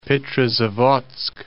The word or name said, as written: Petrozavodsk